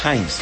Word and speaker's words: Heinz: Es Heinz. – Heinz. – Se escribe con E, pero en verdad se pronuncia A. Los que saben alemán, yo no sé, pero así me contaron que se decía.